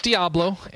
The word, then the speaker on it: Diablo: No, it's Diablo, and you can tell because it's the Spanish word for devil, and that actually has a proper pronunciation.